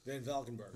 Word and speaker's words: Van Valkenburg: I mean, you know, a lot of people, I think Ken at ESPN, I can never pronounce his last name, Ken Van Kalpen. – Van Valkenburg.